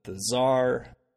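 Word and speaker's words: TzHaar: So Tsar is actually T-Z capital H-A-A-R. – Oh. – Pronounced okay. Z-A-R.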